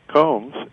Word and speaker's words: Combs: Well, the easiest thing is just to Google my name, which is Alan Combs, and that's spelled with two L's and two A's, so it's A-L-L-A-N. And then Combs is if you combed your hair, you had two or three combs, C-O-M-B-S.